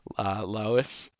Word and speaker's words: Lois: So when he says he had a premonition of Barry Allen saying Laos, God, I'll mispronounce it every time. Lois, Lois Lane, Laos Lane.